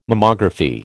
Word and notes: Mammography: American English

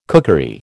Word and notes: cookery: American English

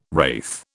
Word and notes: wraith: British English

